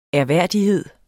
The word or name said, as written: ærværdighed